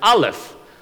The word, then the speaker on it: Aleph: The word or the pronunciation of this first letter of the Bible is Aleph.